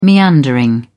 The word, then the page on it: meandering: British English